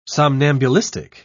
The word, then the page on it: somnambulistic: British English